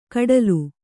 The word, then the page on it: ಕಡಲು: kaḍalu